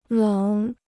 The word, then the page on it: 冷: lěng